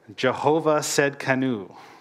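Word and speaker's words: Jehovah Tsidkenu: We've got Jehovah Shalom, Jehovah Rapha, Jehovah Nisi, Jehovah Raha, Jehovah Shammah, and then the one I constantly mispronounce, but I actually looked it up, Jehovah Tsedkenu. So I got that.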